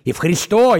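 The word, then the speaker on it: Евхристо (ευχαριστώ): Евхристо, именно ударение на О, блядь. У меня один говорит, Евхристо, блядь. Я говорю, хуй ты не Ев, блядь. И не Ле, блядь, а Евхристо. Они говорят, параколо, пожалуйста, блядь, параколо.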